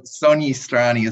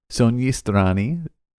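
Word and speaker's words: Sogni Strani: But Soni Srani is spelled S-O-G-N-I space S-T-R-A-N-I. So, yeah, there's a Nia in there as seen in words like gnocchi. – All right. Soni Srani.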